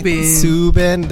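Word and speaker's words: Soobin: Did I pronounce that correct, Stephanie? Subin. There's like three O's. – Exactly.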